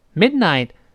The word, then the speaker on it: midnight: midnight mid 中间那个字母 d 失去爆破了，读成 mid 憋下气。 midnight midnight 是半夜啊。那么 she's always making loud noises，那个 loud 又是发一个 l 的声音，loud 最后那个的失去爆破，因为后面又出现别的辅音了。 loud noises noises oi noises at midnight，哎，这么几个音儿把它读准啊。